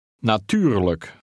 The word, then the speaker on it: natuurlijk: Be careful of your pronunciation of the unstressed ending. – Natuurlijk.